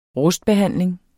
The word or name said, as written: rustbehandling